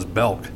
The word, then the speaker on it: Belk: What the hell is Belk? – It rhymes with milk.